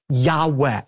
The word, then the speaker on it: Yahweh: What we actually have there in the original manuscripts are the letters Yud-Heh-Vav-Heh, which are God's personal name that most Semitic scholars believe is pronounced a breathy Yahweh.